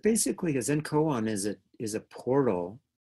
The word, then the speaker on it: koan: And I think Zen Koan, is that how you pronounce it? Koan, not Koan. – Koan.